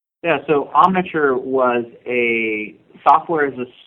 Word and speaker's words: Omniture: So, before you got the urge to start Exact Rail, you had co-founded Omniture. Did I say that right? – That's correct.